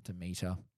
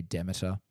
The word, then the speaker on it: Demeter: It depends on what region you're in. Depends on how you pronounce it. I pronounce it Demeter, but all the Americans seem to say Demeter.